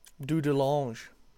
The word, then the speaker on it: Dudelange: Wait, how do you say it? – Dudelange, the French.